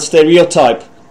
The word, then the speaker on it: stereotype: For the very last time, the pronunciation of the word stereotype. Listen to this. – Stereotype. – Okay, that's it. I kept mispronouncing it because of placing the word stress in the wrong position.